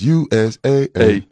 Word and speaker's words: USAA: You see it, USAA is behind it, and they're probably paying you got to say it right. It's USAA.